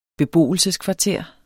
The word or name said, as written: beboelse